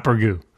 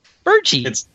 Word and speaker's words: burgee: No, not burgoo. – No, burgee. – It's like little burgoo. – That's spelled B-U-R-G-E-E, burgee.